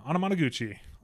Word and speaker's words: Anamanaguchi: Anamanaguchi. Yeah, Anamanaguchi.